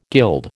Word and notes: guild: American English

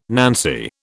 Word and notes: nancy: British English

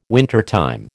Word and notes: wintertime: American English

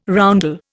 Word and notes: roundel: Indian

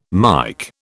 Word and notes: Mike: British English

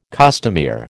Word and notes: costumier: American English

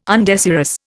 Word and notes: undesirous: Indian

